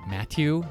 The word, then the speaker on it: Mathieu: Matthew Vanderpool. That's how they say it. Matthew, not Matthew. Matthew. Right. Whatever. – Yeah. M-A-T-H-I-E-U.